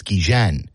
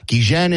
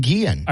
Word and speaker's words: Guillen: Ozzie Guillen has always said, I remember when he was a young player, he told everyone it's Guillen. – Yeah. – Guillen is the way I pronounce my name, but everyone has called him Guillen.